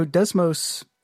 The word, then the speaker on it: Desmos: So Desmos, which is spelled D-E-S-M-O-S, by the way, for those listening, we actually create several tools.